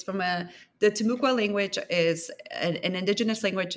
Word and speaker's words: Timucua: So in the first few paragraphs of the book, you introduce the story of Timucua Eve. Am I pronouncing that correctly? – Timucua, yeah.